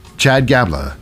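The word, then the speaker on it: Gable: Remember, Chad Gabler, it rhymes with tabla.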